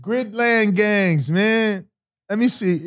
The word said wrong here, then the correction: Gridiron: Oh, Gridiron Gang? – Gridiron. Okay, I'm saying it wrong.